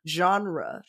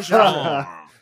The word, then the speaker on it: genre: He, you know, with French words, he's very, very respectful, right, of the French pronunciation. So it's like a supercut of him saying genre. We say genre. The genre. Yeah. This genre. – It's one syllable when he does it.